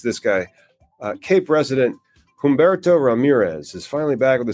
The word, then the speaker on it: Humberto: The H is silent. It's Humberto. – Humberto.